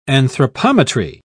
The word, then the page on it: anthropometry: British English